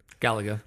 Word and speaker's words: Galaga: Galaga.